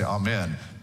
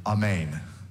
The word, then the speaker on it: amen: But here in Texas, we say it wrong, right? We say amen. But really, if you're to be a Greek speaker, or you Spanish speakers, you do say this correctly, amen.